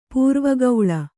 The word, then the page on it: ಪೂರ್ವಗೌಳ: pūrva gauḷa